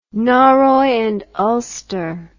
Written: norroy and ulster

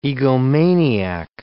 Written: egomaniac

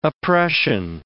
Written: oppression

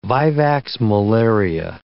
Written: vivax malaria

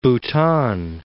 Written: Bhutan

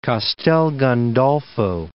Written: Castel Gandolfo

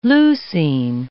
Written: leucine

